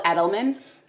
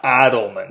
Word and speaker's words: Edelman: It's called Edelman. – Would you spell it for me? – E-D-E-L-M-A-N. – Edelman. – Edelman. – Not Adelman. – No. – Edelman. Yes, okay.